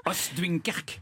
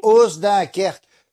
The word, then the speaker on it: Oost-Duinkerke: Et ils les aiment tellement, figurez-vous, qu'en 1950, le syndicat d'initiative d'une ville qui s'appelle Oost-Duinkerke, j'espère que je prononce bien. – Oost-Duinkerke. – Oui, qui a fusionné par la suite avec la commune de Coxyde, hein, c'est en Flandre occidentale, a lancé. – Et c'est Oost-Duinkerke. C'est Oost-Duinkerke.